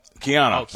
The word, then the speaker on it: Keanu: I think of Canal Reeves, the Matrix guy, Canal Reeves, right? Am I saying his name right? Keanu Reeves? – Keanu Reeves. – Keanu. That's the way you say Keanu.